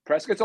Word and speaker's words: Prescott: Oh, Prescott. – Prescott. It's spelled Prescott, but people. – It is 100% spelled Prescott, but if you say Prescott, people don't think you're from Arizona.